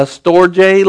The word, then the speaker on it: storge: You have an eros love, a phileo love, a storge love. It looks like storge. It's a G, but you pronounce it with a J.